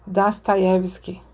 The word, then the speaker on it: Dostoiévski: Ah, uma coisa que eu, eu, tinha dúvidas era com relação à pronúncia né, do, do nome Dostoiévski, e agora eu sei que quem estudou Dostoiévski não fala assim, fala Dostoiévski, porque os Os eles são átonos, eles não têm um acento de intensidade.